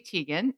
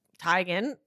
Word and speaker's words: Teigen: You can find, like, if you Google Chrissy Teigen Teigen pronunciation, there are lots and lots of interviews where she was like, actually, it's Teigen, but it hasn't stuck.